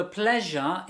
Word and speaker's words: pleasure: The pleasure is all mine. The pleasure is all mine. – Y ahora vamos a ver esa pronunciación. – Be careful, it's pleasure. Pleasure. Pleasure.